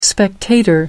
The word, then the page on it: spectator: American English